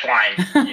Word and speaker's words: Schleien: Yeah, Schleien. You got it.